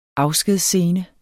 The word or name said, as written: afskedsscene